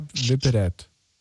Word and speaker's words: Вперед: Всегда вперед.